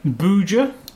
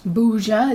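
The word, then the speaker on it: Bhuja: Now we're going to talk about this little snack thing that we got I don't know how to pronounce that. – Buja. – Buja. It's B H U J A.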